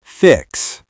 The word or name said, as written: fix